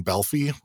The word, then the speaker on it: Balfe: Just getting into it, the composer for the series is Lorne Balfe, and I have to say mea culpa, because up until now I had been saying Lorne Balfe, which is incorrect. His the correct pronunciation is Balfe. Apparently I was trying to pronounce it like the old tongue.